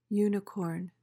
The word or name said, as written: unicorn